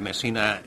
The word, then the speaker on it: Messina: Or do you say Messina? I think Messina is the Italian word, and that's the way it should be pronounced.